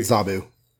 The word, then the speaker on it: Zabu: Kesar and Sabo, is that his name? Sabo, Sabretooth Tiger, show up and chase them away. – I pronounce it Zabu.